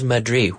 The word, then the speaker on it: Madrid: What is Madrid? Where is that? What country is Madrid? I don't know what that means. Because the D has to be pronounced.